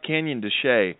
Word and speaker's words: Canyon de Chelly: Yeah, it's pronounced Canyon de Chelly, and it's basically a beautiful long serpentine canyon that extends from the border of New Mexico well into Arizona.